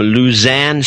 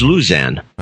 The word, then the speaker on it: Lausanne: From Lausanne, Switzerland. – Lausanne. – That was Lausanne. – I think it's Lausanne. Lausanne. – I think maybe that's where the Dutch pronounce it.